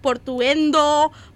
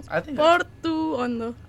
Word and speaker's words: Portuondo: Like, they say, like, Portuendo, Portondo. Like, Portuando. I was like, just follow the pronunciation. Portuondo.